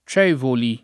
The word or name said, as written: Cevoli